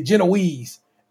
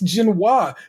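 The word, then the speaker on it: génoise: So everybody in that motherfucker calling that shit Genoese. And I'm just like, I know that's not right. Because it's not right. It's Genoise.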